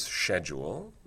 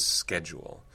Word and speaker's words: schedule: Because it exists in English with, let's say, now two pronunciations, two valid pronunciations. One of those is schedule, and the other is schedule. And I suppose we could say that schedule is winning. There are fewer and fewer people who pronounce the word schedule.